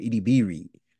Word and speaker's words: Edebiri: Edebiri.